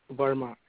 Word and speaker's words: Verma: It is Karan Verma. It is spelled K-A-R-N, and last name is spelled V-E-R-M-A.